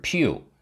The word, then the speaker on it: Pu: 不的符号是 Pu，这同样用的是名称里的第一和第三个字母的组合。可是不的前两个字母形成的对儿 Pl 并没有和任何已知元素重复，为什么他也舍弃了这个常规的选项呢？这就是这几个男孩的私心了。 pu 的发音是 pu，这是形容臭味的感叹词。